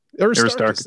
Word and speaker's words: Aristarchus: But I'm going to mess this up, I'm sure. – Aristarchus? – Aristarchus. There we go.